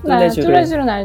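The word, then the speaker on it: Tous les Jours: 뚜레쥬르는 알죠. – 무슨 말이에요, 이거? – 뚜레쥬. 매일? – 오, 발음 좋아.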